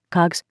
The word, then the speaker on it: COGS: Cost of goods sold, also known as, and pronounced, COGS, or cost of sales.